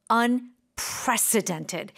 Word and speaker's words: unprecedented: the accent is on unprecedented. Precedented. – Precedented. – Yeah. And you really do need to stress that because mm-hmm.